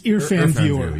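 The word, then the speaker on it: IrfanView: I'll spell it for you. IR, as in Richard... F as in Frank, A-N, View, EarFan View. It's all one word.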